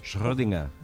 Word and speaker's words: Schrödinger: ¿Cómo se pronuncia Schrödinger? Bien. Correctamente. – Schrödinger.